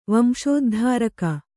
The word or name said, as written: ವಂಶೋದ್ಧಾರಕ